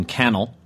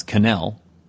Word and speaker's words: Cannell: the short-lived live-action superhero series from Stephen Cannell, whose name I used to always pronounce Cannell, but it's actually Cannell, as I discovered, that ran from 1981 to 1983.